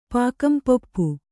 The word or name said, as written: ಪಾಕಂಪೊಪ್ಪು